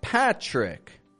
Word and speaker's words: Patrick: But my full name is, of course, Patrick, with this kind of chur, chur sound.